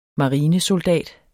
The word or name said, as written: marinesoldat